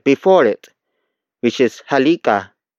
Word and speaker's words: halika: The first word is halika, halika, halika. Halika is spelled as H-A-L-I-K-A.